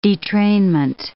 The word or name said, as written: detrainment